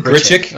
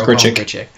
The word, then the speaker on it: Grichuk: Sorry, how do you pronounce his name again? – I've heard it Grichuk. – Okay. – That's what I've been like just watching Cardinal games on TV. That's what they've been calling him, Grichuk.